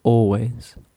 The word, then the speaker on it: always: So you say always, okay, always, you know, like Bon Jovi, I will always love you, always, okay, be careful, don't pronounce the L, la L no se pronuncia, es muda, so you say always,